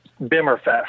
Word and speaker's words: Bimmerfest: I mean, you wrote for Bimmer Magazine, so these are Bimmerfest. How do you say it? How do you say it? – Bimmerfest. – That's what I thought, yeah.